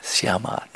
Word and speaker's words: Syama: Yes, Vasudev Krishna had turned into a beautiful young little maiden, and his name was now, not surprising for you, Syama with long A in the end. Syama, not Syam, Syama. It's very close.